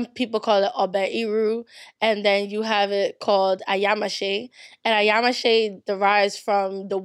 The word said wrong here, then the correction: Ayamase: Or I think how they would pronounce it is ayamase. Yeah, ayamase. Yes.